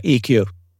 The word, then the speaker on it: Ikkyu: it, Uh, EQ.